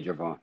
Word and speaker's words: Gervon: Yeah, Gervon.